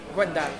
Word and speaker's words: Gwendal: Gwendal.